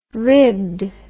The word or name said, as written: ribbed